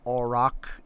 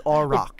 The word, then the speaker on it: aurochs: I believe it is pronounced auroch. – Yes, I recant that. It is aurochs. – Good on you. And here's why it is aurochs. It comes from the Greek. It comes from Greek and German.